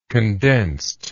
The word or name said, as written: condensedness